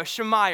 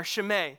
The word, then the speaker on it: Shimei: Do you remember this guy from a few weeks ago, Shammai or Shimei? His name could be pronounced either way.